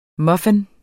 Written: muffin

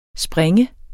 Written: springe

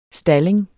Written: stalling

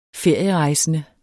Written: ferierejsende

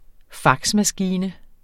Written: faxmaskine

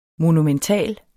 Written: monumental

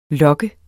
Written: lok

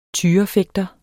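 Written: TYREFÆGTERES

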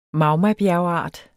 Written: MAGMABJERGART

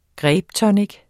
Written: grapetonic

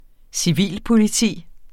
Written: civilpoliti

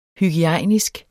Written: hygiejnisk

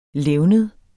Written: levned

